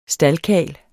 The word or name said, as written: staldkarl